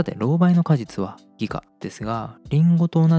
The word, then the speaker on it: 擬果: 偽の果実と書いて擬果と読みます。